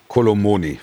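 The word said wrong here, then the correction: Kolo Muani: Falsch. Kolomwani. Muani. Kolomuni. Nein, falsch.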